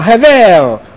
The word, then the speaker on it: hebel: Now the word "meaningless" is actually translated from a Hebrew noun, hevel, or some pronounce hevel. H-E-B-E-L, that's how we write it out, transliterated in English, Hebel.